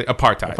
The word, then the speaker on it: apartheid: The H is silent. It's really apartheid.